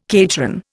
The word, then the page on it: cateran: Indian